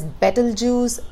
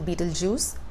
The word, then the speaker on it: Betelgeuse: The name of the star itself can be pronounced in four different ways. There's Betelgeuse, Betelgeuse, Betelgeuse, Betelgeuse. It's just easier to pronounce it Betelgeuse.